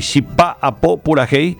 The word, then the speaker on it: Chipa Apo Purajei: Nada más para comentarles a nuestro querido amigo de Folclorama, Chipa Apo Purajei, Chipa Apo, así vamos a pronunciar en guaraní, Chipa Apo Purajei, que significa elaboración de la chipa en guaraní.